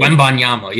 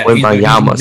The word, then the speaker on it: Wembanyama: I forgot how to pronounce the last name. – Wembanyama, yeah. – Wembanyama.